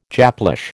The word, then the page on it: Japlish: American English